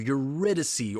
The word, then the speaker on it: Eurydice: And we left off last week right in the middle of our discussion of Eurydice, or Eurydice, if you're being Eurydice. – Or Eurydice, yeah. Okay, is the Italian pronunciation.